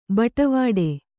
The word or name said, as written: ಬಟ್ವಾಡೆ